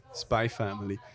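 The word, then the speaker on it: Spy × Family: Spy X Family geschrieben, aber äh, vor allem auf Wikipedia steht sogar noch and pronounced Spy Family.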